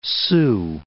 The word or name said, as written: Sioux City